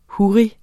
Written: huri

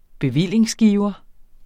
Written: bevillingsgiver

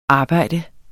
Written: arbejde

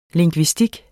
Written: lingvistik

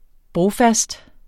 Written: brofast

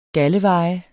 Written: galdeveje

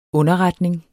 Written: underretning

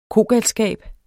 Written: kogalskab

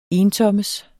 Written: entommes

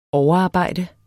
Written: overarbejde

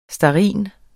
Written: stearin

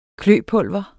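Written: kløpulver

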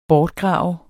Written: bortgrave

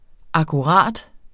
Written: akkurat

